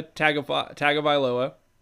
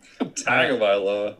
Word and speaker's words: Tagovailoa: Tagovailoa. – Tagovailoa. – Yeah. – Tagovailoa.